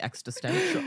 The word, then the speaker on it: existential: Existential. – Existential. – I'm adding an extra T in there.